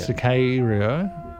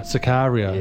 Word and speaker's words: Sicario: I have way more A in mind. – Like A, yeah. Sicario. Sicario. – Yeah, I hang on that a bit.